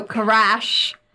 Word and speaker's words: Karash: So Karash is how it's pronounced, has a werewolf-like body with topaz eyes, and it measures up 10 feet long on all fours.